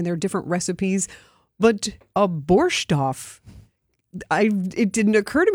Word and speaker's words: borscht: So in Ukrainian, we usually say borscht.